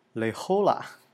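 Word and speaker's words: La Jolla: 圣地亚哥最有名、最美的一个海滩啊，叫 Le Hola，然后它是一个西班牙语发音。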